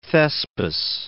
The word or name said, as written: Thespis